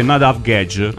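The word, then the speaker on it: Nadav Guedj: Tra l'altro ci ha scritto il nostro amico Yusuf Alakmed, insomma non riesco a pronunciare il suo nome, e ha detto che si dice Nadav Gedge.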